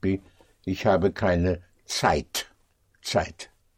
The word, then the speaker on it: Zeit: It's spelled Z or Z, E-I-T. And that is pronounced like a T-S, Zeit.